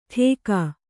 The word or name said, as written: ಟೇಕ